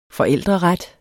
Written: forældreret

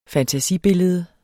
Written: fantasibillede

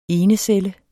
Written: ENECELLEN

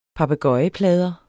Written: papegøjeplader

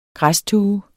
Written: græstue

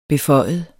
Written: beføjet

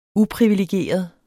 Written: uprivilegeret

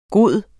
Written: bedste

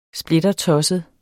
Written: splittertosset

